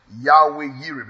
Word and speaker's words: Yahweh Yireh: Now, it is spelled Yahweh Yireh, because there was no J in the vocabulary alphabet.